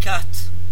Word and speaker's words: quatre: Now, I've heard it before, and I was just thinking when we were going through that pronunciation exercise there that I've heard many a French person say quatre instead of quatre.